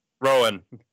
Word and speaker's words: Rowan: And we have special guest, Mr. Justin Rowan, or Rowan. How the hell do you say your last name, man? – Rowan.